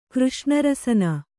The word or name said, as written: ಕೃಷ್ಣರಸನ